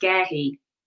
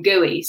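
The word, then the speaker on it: Guéhi: We think it's pronounced Gehi. – Yeah, maybe I should have looked that up before saying, but... – One of the commentators calls him Gooey, so I think anything goes at this point.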